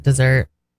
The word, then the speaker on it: Mount Desert: Well, and also you're supposed to pronounce it dessert because it's French.